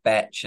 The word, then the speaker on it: bach: And for those of you who don't know what a batch is, it's spelled B-A-C-H and there's no T in it.